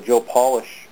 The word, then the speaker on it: Joe Polish: And actually, Pat was the one who opened my eyes to the coaching process because he was a Joe Polish, I believe that's how you say it, yep.